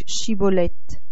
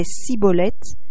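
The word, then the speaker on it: Schibboleth: Alors il lui disait : Dis donc Shibolette. Et mais il disait Sibolette, car il ne pouvait pas bien prononcer.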